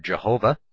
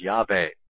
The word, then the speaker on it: Yahweh: Christians erroneously pronounce the word Jehovah, they say, and of course they do. Christians erroneously pronounce the word Jehovah, and modern emphasis ours. Modern scholarship suggests that its original pronunciation was Yahweh.